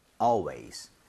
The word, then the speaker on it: always: Always가 아니라 AL은 항상 R에 가깝게 입 모양 벌리셔야 됩니다. Yes. 한번 발음해 볼게요. Always.